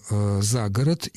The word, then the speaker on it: за город: Речь идет о допустимости переноса ударения с существительного на стоящий перед ним предлог. Это допустимо, это очень по-русски, и это не является ошибкой. Более того, когда мы говорим «за город», именно этот вариант является основным сегодня.